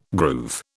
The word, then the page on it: groove: British English